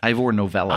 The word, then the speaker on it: Ivor Novello: Ivor Novello.